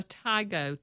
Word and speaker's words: Thai-Go: And last Monday night, I did what's called a Tygo tasting. So in other words, I have a product called Tygo, and it's spelled THAI-GO, and it's a juice, and the blend of fruits that are in this juice come from the islands, some of them.